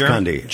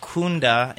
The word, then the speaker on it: Kunde: It's Kunde. German name, and it's pronounced Kunde in German, and that is stands for customer or client.